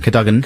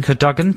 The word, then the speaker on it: Cadogan: Cadogan. – Cadogan. Cadogan. I read it phonetically there. Cadogan.